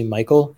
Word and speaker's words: Michel: The prosecuting attorney, it's spelled Michel, but it's essentially Michael in English.